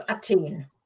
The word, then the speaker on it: Athen: Yeah, except in German it's Athen.